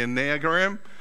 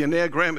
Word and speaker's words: Enneagram: Yes, I do pronounce it differently. I call it the Enneagram. It's because the first word is 9 in Greek. So, ena dia tria tesra pende exiapta to enea. So, it's the Enneagram.